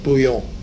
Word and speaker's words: bouillon: That's why that name, it's French, of course, but the Belgians pronounce it the same way, bouillon.